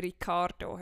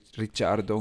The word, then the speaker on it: Ricciardo: Ja gut, der Stäubli sagt dafür Ricardo he? und wir sind ba, ba, ba, ba, ba, ba, ba. Das, das, ist, das ist ja noch etwas anderes das ist ja wirklich halt ein Australier, der italienische Wurzeln hat und man sie eigentlich anders müsste aussprechen. Also Rich Ricciardo.